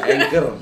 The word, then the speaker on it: Anchor: lihat aplikasinya hancur gitu ya. – Hancur? Apanya hancur? Anchor. Anchor mah Anchor. Hancur. Iya kan anchor.